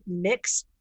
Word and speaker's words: Myx: And her name is Erica Shannon, and she is the fitness director for a company called Mix Fitness. It's spelled M-Y-X Fitness.